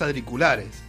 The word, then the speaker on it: auriculares: Audri. – No, ¿cómo se dice? – Auri. – Auri. – Auri. – Auri. – Auriculares. – Auriculares. Ya está, ¿ves? Me confundiste. Estos Audri, cosas.